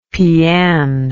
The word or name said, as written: pian